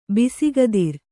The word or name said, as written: ಬಿಸುಗದಿರ